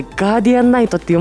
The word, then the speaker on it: 守護騎士: でもしかもこれ守護騎士って書いてガーディアンナイトって読ませるんですよね。 – そうですね、そう読ませます。